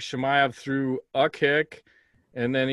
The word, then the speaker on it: Chimaev: Chimaev.